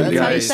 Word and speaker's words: Rheingeist: Yeah, Rheingeist.